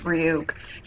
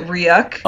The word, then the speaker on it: Ryuk: Light reads the name in the Death Note because the Death Note specifically mentions Ryuk. He pronounces it Ryuk.